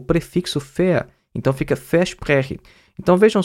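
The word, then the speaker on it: ver: fea. Né? Fea é porque a gente está fazendo a pronúncia correta do alemão, porque na verdade é V-E-R. Né? E o V no alemão tem um som de F.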